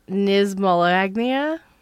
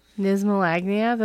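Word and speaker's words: knismolagnia: So, it's called Nismolagnia. – Oh. What'd you call me? – Nismolagnia. That starts with a K, by the way. – Of course it does. – K is silent.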